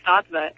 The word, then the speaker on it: Sattva: M-A-M-A, like the Spanish mama, and Sattva, S-A-T-T-V-A.